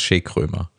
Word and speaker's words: Chez Krömer: Das aber ich glaube, es heißt Chez Krömer. – Ja, Chez, das ist Französisch.